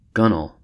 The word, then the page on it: gunwale: American English